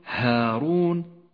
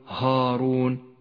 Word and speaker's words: هارون: والآن الألف المرققة في كلمة هارون، رققها ولا تفخمها، فقل هارون ولا تقل هارون فإنه خطأ شائع.